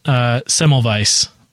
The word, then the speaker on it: Semmelweis: Semmelweis. With the W, it's pronounced like a V.